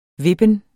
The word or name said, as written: vippen